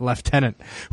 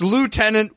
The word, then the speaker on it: lieutenant: I want to know how many outtakes there are of the English actors in Star Trek, especially Patrick Stewart, who mispronounced it lieutenant, who then had to cut, it's lieutenant, this is American, and then they had to do it again.